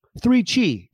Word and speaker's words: 3Chi: 3Chi, it rhymes, is the industry leader in Delta-8 THC products.